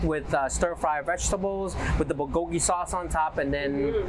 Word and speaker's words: bulgogi: Today's special at this event, we're going to showcase one of my most favorite item is the Korean-style bulgogi rice bowl.